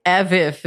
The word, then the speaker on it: AVIF: I think AVIF is how you should say it. I think that sounds cool.